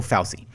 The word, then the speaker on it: Fauci: Is it Dr. Fauci? We're going to go Fauci.